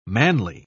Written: manly

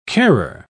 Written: carer

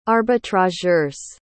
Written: arbitrageurs

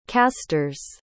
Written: casters

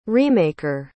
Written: remaker

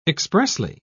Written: expressly

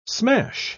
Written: smash

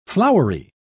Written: flowery

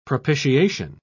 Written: propitiation

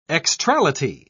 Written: extrality

